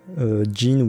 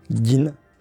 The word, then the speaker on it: Gin: Mais ça, moi, j'ai regardé la version japonaise, comment ça s'écrit, et c'est bien G-I-N. Donc, c'est différent de Jin ou Jing. C'est vraiment. Et normalement, ça se prononce Gin. On entend. Enfin, moi, je suis pas bon en japonais, mais dans la vidéo, on les entend dire Jin, Jin, un truc comme ça. C'est difficile à prononcer.